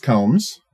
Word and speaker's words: Combs: Definitely pronounced Combs. – Oh, I said Combs.